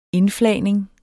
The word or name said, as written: indflagning